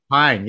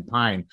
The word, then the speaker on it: Pijn: Pine, yeah, okay. P-I-J-N, Pine. – Yeah, it's Pine.